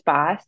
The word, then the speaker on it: Bos: So my name is Alex Boss, and thank you so much for pronouncing my last name correctly. I only get that every once in a blue moon. So it is B-O-S, but pronounced Boss.